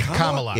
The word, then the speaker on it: Kamala: Is it Kamala? – It's Kamala.